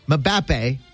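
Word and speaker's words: Mbappé: Mbappe.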